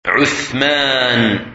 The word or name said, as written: Uthman